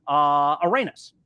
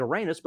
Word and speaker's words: Arenas: the pronunciation isn't Arenas, but it's Arenas.